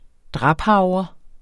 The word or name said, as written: draphavre